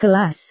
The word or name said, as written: kelas